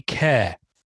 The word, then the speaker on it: Kjar: Andy Kerr, whose surname is pronounced Kerr, but it's spelt K-J-A-R.